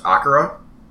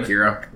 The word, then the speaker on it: Akira: And long story short, if you don't know what, and I believe it's actually pronounced Akira. – Fine. – Instead of Akira.